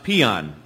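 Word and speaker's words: paean: There was another time, too, when there's a word in the English language that means joyous song, but it's pronounced peon.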